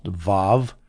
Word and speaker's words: Vav: Now, I saw a very interesting comment on YouTube by a scholar who was saying it should be pronounced Vav because most Jews pronounce it that way. There's some Jews who have Arabic as their native language who pronounce it Waw. But he says, no, it should be Vav, so I'm going to take his word for it.